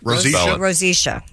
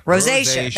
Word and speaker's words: rosacea: Rosacea, yes. Oh. – Rosacea.